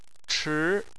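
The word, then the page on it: chi2: tone 2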